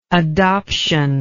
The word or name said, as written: adoptional